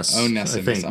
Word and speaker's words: Onesimus: Onesimus.